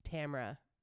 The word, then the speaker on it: Tamora: A-R-A. We were like, is it Tamara? Tamara? So choose your own adventure, but in the case of Tamra Pierce, it is Tamra rhymes with camera.